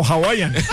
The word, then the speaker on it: Hawaiian: No, Hawaiian. Al principio lo dijo bien.